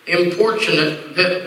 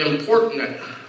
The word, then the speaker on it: importunate: To look at it, you try to pronounce it "importunate," but I looked it up. It's "importunate." It has a C. It's pronounced with a CH, which simply is just another word that means insistent, unceasing, begging.